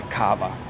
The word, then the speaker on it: Cava: Der Cava. 4 Buchstaben, in allen Sprachen genauso ausgesprochen, als neue Bezeichnung für den Champagner aus Katalonien.